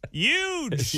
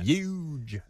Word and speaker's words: huge: Huge. – No, it's huge. Huge. Say the H with me, all right?